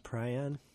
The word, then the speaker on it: prion: I'm pronouncing prion correctly, just for all the sticklers. – Sure? It's not prion? – It pries on. As some have said, but no, it's a prion.